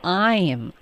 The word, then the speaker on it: I'm: 这里啊我就想请问了，老师您说那个 I 这个 I am 哈，这里的发音是读作 I am 还是 um？ – um 是一个弱读，然后如果我们要说 but I'm，一般我们就直接在这个词组中呢就肯定会把 I'm 弱读为 um，所以就发 but I'm but I'm，这里它是一个弱读。